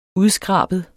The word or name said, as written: skrab